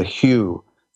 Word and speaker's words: hu: You can also, very similar to ooh, is the hue that Ekinar says is the sound of love. Ekinar likes H-U, hu. It's mostly an U, hu, hu, hu. There's also the Tibetan warrior syllable for love, ancient Tibet, and that's hung, H-U-N-G.